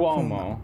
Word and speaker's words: Cuomo: Is it Cuomo or Cuomo? Cuomo. Okay, I always say it wrong.